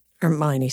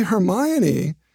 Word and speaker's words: Hermione: maybe we've been mispronouncing it for 400 years. We all say Hermione, but if you look at the lines, he's beat from his best ward, well said, ermine, and leave you to your graver steps, ermine. Thou art ermine, or rather thou art she. Those are 10-syllable lines. If you say Hermione, you've got 12-syllable lines.